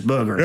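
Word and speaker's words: Beuger: And it's spelled completely different. It's B-E-U-G-E-R. My family came from France. – Well, if I remember my high school French, wouldn't that be pronounced more like Bouger? – No, it's Booger.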